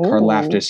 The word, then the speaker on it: Karlaftis: Karlaftis.